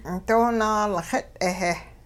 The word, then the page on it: doo-naalxit'-heh: hupa